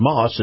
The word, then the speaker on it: Maus: Now, this portion of the program is brought to you by our friends at Moss. It's spelled M-A-U-S, Moss Acura.